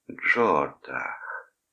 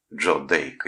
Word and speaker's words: Джордах: Ему нравилось, как мисс Лено произносит его имя. Произносит медленно, нараспев, так, как ему нравится. Джордах. Мягко, просто музыкально. А не какой-то там Джордах. Или хуже того, как произносят некоторые. Джо Дейк или Джордек.